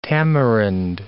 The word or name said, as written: tamarind